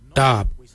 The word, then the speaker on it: dhaab: For knife, we say darb. Darb is spelled D-H-A-A-B.